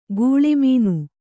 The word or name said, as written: ಘುಳುಮ್ಮನೆ